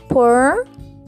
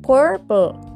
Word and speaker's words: purple: Se pronuncia purple colocas la lengua en el paladar, purple, purple. Purple. Es como pur, colocas la lengua en el paladar y la PL. Pl, purple. Purple.